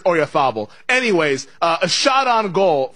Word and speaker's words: Oyarzabal: I mean, but like the golfer Jose Maria Oyarzabal or whatever, like nobody was pronouncing the Z.